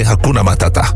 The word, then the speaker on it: Hakuna Matata: Niritata? Nee, Hakuna Matata.